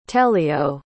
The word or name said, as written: thélio